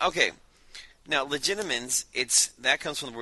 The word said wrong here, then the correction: Legilimens: We're all pronouncing this wrong. It's legilimens with an L. – Legilimens? – Legila, read. From the Latin legere, read.